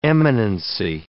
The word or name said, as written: eminency